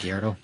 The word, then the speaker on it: Gallardo: On the Lamborghini Gallardo. Is that how you say it? Gallardo? – Yeah.